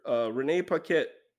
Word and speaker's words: Renee Paquette: It was convoluted where Renee Paquette, if I'm saying her name right, that's correct.